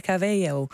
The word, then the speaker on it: Cabello: Hooguit Camila Cabello. De B van spreek je in het Spaans niet uit. Dan zeg je een beetje Cabello.